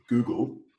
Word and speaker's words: Google: En inglés es Google, pero para muchos que están aprendiendo inglés, ¿cómo se pronuncia? – Bueno, a veces se escucha hasta Google, ¿no? – Google. – Google, Google. – o un Google raro, ¿no? – Bueno, aquí el problema es el sonido de las vocales inglesas.